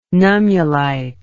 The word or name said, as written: nummulite